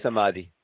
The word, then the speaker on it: samadhi: Is it Samadhi. – Okay. – Samadhi.